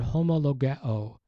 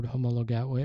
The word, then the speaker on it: homologeo: The Greek verb confess or acknowledge in the NIV is the word homologeo. It's spelled H-O-M-O-L-O-G-E-O.